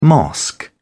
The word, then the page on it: mosque: British English